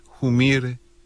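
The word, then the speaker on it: Humire: Joseph Humair, pero como se pronuncia originalmente en Bolivia, Humair, es uno de los funcionarios más importantes del Pentágono, como se conoce al Departamento de Defensa de Estados Unidos.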